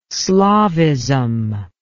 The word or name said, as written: slavism